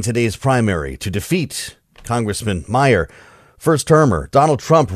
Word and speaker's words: Meijer: One of the 10 impeachers, a guy who spells his name M-E-I-J-E-R, but they pronounce it Meyer.